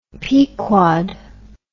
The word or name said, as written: Pequod